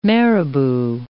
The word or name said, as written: marabout